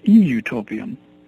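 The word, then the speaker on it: eutopian: It's utopian, typical spelling, U-T-O-P-I-A-N, but it has an E in front of it. It's E-utopian.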